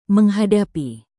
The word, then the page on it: menghadapi: Indonesian